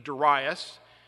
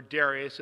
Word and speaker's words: Darius: During the second year of the reign of the Persian king Darius, or Darius, it's like Augustine or Augustine, depends on what syllable you put the emphasis, Haggai preached from August 29th, 520 BC until December 18th, 520.